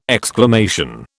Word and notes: exclamation: British English

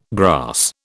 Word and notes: grass: British English

